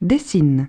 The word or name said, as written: dessines